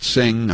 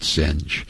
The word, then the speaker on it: Singh: Now 55, I know it's, you pronounce it Singh, I pronounce it Singe,